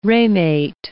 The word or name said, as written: ramate